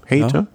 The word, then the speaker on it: Hayter: Vielleicht kurz auch zur Einordnung, Gesamtklassement, David Godu vor Brandon McNulty vor Ethan Hayter. Ist das richtig ausgesprochen? Hayter. Ja.